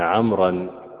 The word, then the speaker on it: عمرو: ويدل على أنها ليست له، أنه لا أثر لها في أثناء القراءة عمر.